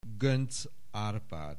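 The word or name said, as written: Göncz Árpád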